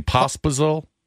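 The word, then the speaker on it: Pospíšil: I don't even know how to pronounce his last name, Pospisil? – Yeah, that's pretty close.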